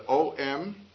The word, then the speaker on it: Om: So this can be written either O-M or A-U-M, and you'll see it both ways. When one pronounces Om, the sound starts in the back of the mouth, ah, and then it goes, fills the mouth cavity, oo, and then it closes at the lips, mm.